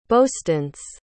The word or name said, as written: boastance